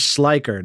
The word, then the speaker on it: Schleicher: we are very, very happy to be joined by our friend James Schleicher.